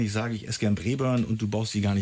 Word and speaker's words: Braeburn: Ja, Braeburn ist schon richtig ausgesprochen.